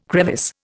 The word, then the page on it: crevice: Indian